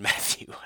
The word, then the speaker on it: Mathieu: I believe his last name is pronounced Matthew.